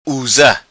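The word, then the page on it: Usa: Macanese Patuá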